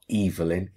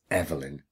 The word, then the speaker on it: Evelyn: Not one person in that production office has stopped and said, no, the name Evelyn is actually a Scottish name and it's pronounced with a hard E at the start. It's not Evelyn, it's Evelyn.